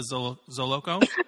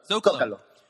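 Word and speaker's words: Zócalo: the Zoloco. How do you say it? – Zocalo.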